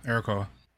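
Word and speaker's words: Arakkoa: Yeah, Arakoa.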